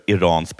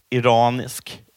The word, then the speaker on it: Iran: Var på en iransk från en statsmedia då, journalist tar mikrofonen och säger detta. – You're pronouncing our country's name wrong. Our country is named Iran, not Iran.